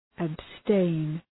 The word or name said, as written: abstain